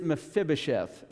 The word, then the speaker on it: Mephibosheth: His name is Mephibosheth.